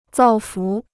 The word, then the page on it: 造福: zào fú